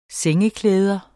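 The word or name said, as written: sengeklæder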